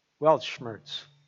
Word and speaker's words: Weltschmerz: It's spelt W-E-L-T. S-C-H-M-E-R-Z. Anybody pronounce that? Weltschmerz. Weltschmerz. W-E-L-T-S-C-H-M-E-R-Z.